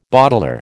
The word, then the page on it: bottler: American English